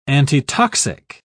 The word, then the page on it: antitoxic: British English